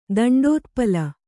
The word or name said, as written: ದಂಡೋತ್ಪಲ